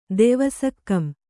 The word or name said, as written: ದೆವಸಕ್ಕಂ